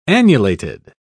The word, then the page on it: annulated: British English